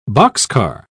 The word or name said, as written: boxcar